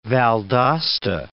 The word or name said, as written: Valdosta